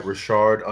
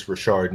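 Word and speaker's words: Reshard: And then on every other platform, literally, it's Rashard Neil. I'm not finna say every platform. I will tell you how to spell it, though, because people butcher it. That's R-E-S-H-A-R-D. Last name, N-E-A-L.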